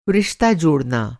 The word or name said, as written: जोड़ना